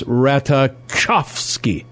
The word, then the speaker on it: Ratajkowski: It's Ratajkowski. Okay. Ratajkowski. Not Ratajkowski.